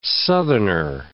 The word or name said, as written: southerner